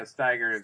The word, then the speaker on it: Steiger: Do you pronounce it Steiger? I said Steiger before. No, that's right. – Yeah, Steiger.